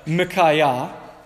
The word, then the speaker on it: Micaiah: So in Hebrew, you would say his name like Micaiah, who is like Yah, who is like Yahweh.